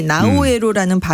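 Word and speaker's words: Naoero: 외국인들이 나우에로라는 발음을 제대로 하지 못해서 나우루라는 이름이 생겨났는데 예.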